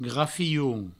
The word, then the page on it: grafion: grafioun; Provençal